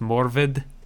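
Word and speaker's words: Morfydd: It's M-O-R-F-Y-D-D, which in that parlance is pronounced Morvid.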